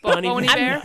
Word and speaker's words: Bon Iver: Oh, also, in 2012, is it pronounced Bon Iver? Bon Iver? – Oh, Bon Iver.